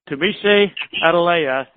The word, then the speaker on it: Tunmise Adeleye: My name is pronounced Tunmise Adeleye. Tunmise Adeleye. It's a little bit foreign.